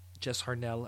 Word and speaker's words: Jess Harnell: It was Kevin Conroy, Jess Jess Harnell, uh, Jess Harnell, I guess it would be the proper pronunciation.